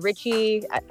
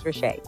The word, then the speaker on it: Ritchea: Yes, it's a popular pronunciation. I get Ritchea, I get Ritchie, and the list can go on. – Yeah. – So, the appropriate pronunciation is Ritchea.